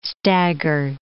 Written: stagger

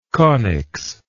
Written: conics

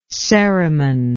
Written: ceriman

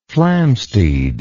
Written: flamsteed

